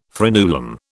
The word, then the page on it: frenulum: British English